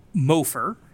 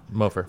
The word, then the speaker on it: Mofor: And he was getting phenomenal run support by Carl Mofer. Believe I'm saying that right. Mm-hmm. M-O-F-O-R. Yep. Mofor, I hope. – Mofer.